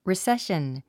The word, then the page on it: recession: riséʃən; リセション